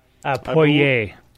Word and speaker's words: Poirier: Well, Dustin so, Poirier. Did I say that one right? – Poirier. – Poirier. – See? – Poirier. – Said it right.